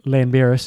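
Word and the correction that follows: Llanberis: as you pronounce it, is Clanberis. – Clanberis. – It's like double L, I think, and it sounds like a bit like that. – Yeah.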